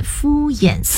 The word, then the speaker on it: 敷衍: 成语用对了，但是正确的读音是敷衍塞责。敷衍意思是马虎、不认真，表面上应付。写法，敷是敷药敷面膜的敷，衍是行走的行，中间还插入三点水。 – 哦，原来读作敷衍，我一直都读敷衍，原来不是第二声而是第三声，衍敷衍。